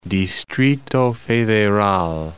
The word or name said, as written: Distrito Federal